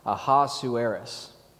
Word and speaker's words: Ahasuerus: The bedecked capital city, city called Susa, is the seat of a Persian king named Ahasuerus, uh, which is how it's pronounced in Hebrew.